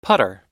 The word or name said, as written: putter